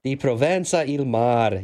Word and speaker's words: Di Provenza il mar: Di Provenza il Mar.